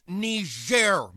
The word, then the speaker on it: Niger: Oh, don't tell me people are still mad about that nigger thing. – It's pronounced Niger, Mr. President, and it's a country in Africa.